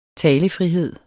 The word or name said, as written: talefrihed